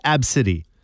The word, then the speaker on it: Abcde: There was a woman in the States who has a 5-year-old daughter whose name is spelled, I'll spell it for you first, ABCDE. – Yes. – Okay, that's how you spell her name. She's 5 years old. It's pronounced Absidy.